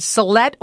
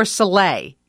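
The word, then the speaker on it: salet: A lot of people are saying that Ellen on her show said Solette or Soleil, you can pronounce it either way, is the word you should start off with.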